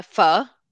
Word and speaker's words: Pho: It's for now a pho, like strong F, strong F. Pho.